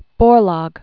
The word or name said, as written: Borlaug